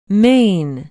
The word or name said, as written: maine